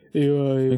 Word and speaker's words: XCX: J'ai jamais compris comment on l'a prononcé, Charlie XCX. – Ouais, ok, ok. Et XCX. Voilà.